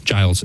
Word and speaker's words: Giles: In French, I think it would be Saint Gilles, I think, is how it would be pronounced, but Giles in English.